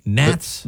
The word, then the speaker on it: gnats: Gnats? – The silent G. It's like, gnats? No, that's not how you say it.